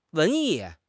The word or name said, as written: 文艺